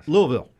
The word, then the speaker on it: Louisville: It's 2.5 syllables for guys. – Louisville.